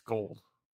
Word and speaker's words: Gould: It's Gold. He played for Chicago long enough. I think I know how to pronounce his name.